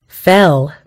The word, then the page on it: fell: American English